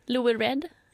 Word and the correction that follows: Lou Reed: Hur uttalar du hans namn? – Louis Reed. – Lou Reed? – Aha, Lou Reed.